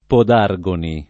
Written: Podargoni

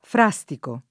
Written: frastico

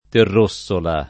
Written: Terrossola

